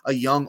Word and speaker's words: Jung: He kind of reminds me of a young Austin Riley. Through 71 games, 280 at-bats, Josh Jung, spelt J-U-N-J, but it is pronounced Young.